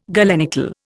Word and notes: galenical: Indian

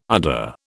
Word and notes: udder: British English

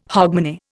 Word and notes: hogmanay: Indian

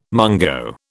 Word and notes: mungo: British English

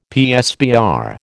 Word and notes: PSBR: American English

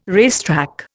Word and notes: racetrack: Indian

